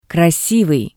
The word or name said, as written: krasivii